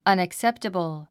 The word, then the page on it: unacceptable: ʌ́nəkséptəbl; アネクセプタボォ